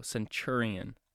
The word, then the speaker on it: centurion: And it doesn't really look like it says centurion, but I looked up the pronunciation and that's what it told me. So if you guys are reading that, it kind of looks like centurion, you know, but it's chur. So centurion.